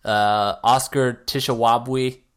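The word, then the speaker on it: Oscar Tshiebwe: Oscar Tishawabwe, did I say that right? – Yeah, the big man.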